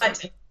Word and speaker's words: Hutton: Hutton, yes.